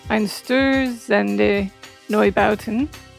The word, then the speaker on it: Einstürzende Neubauten: This is Einstürzende... No, wait a minute. That's not how you say it. Einstürzende Neubauten. I can say that book.